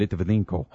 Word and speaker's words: Litvinenko: Litvinenko.